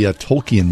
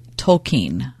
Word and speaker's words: Tolkien: And he corrects the professor and says, Tolkien.